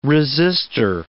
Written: resister

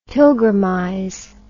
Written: pilgrimize

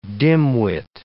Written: dimwit

